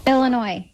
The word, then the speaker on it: Illinois: Illinois.